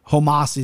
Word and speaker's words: Homasi: Hit Sabah with some Homasi, is how you say his name.